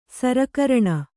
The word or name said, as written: ಸರಕರಣ